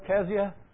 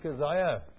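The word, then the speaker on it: Cesia: Although you would probably wonder why I didn't pronounce it Keziah, because it's the Welsh pronunciation. There's no Z in Welsh and there's no K. It was a kicking K one time, but it's gone. And so it's C-E-S-I-A.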